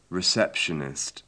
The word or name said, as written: receptionist